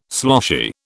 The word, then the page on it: sloshy: British English